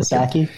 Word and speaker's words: Psaki: Psaki, I believe is her last name. – Psaki, yeah. – And I think the P is silent.